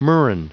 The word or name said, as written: murrain